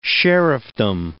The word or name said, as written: sheriffdom